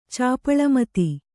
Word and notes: ಚಾಪಳಮತಿ: Kannada

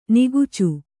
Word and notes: ನಿಕೋಚ: nikōca

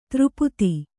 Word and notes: ಧಾರಾಪಾತ: kannada

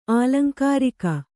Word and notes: ಆಲಂಕಾರಿಕ: ālaŋkārika

